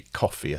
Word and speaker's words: Ko-fi: is it Ko-fi? It's supposed to be coffee, I think. K-O-F-I.